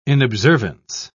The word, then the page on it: inobservance: British English